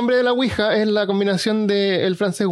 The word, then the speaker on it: Ouija: Y la Ouija deletrió, la palabra Ouija y se lee Ouija. En inglés le dicen Ouija, Ouija board, pero es Ouija. Sí. Y, en el tablero dice que se pronuncia Ouija o Ouija en, en inglés.